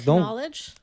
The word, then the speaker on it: knowledge: It's the sound that the K and the N make because the K is silent. And then it's all pronunciation. Anyways, it's don't give me knowledge.